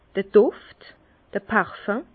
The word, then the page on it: d'r Dùft, d'r Parfùm: Bas-Rhin; Herrlisheim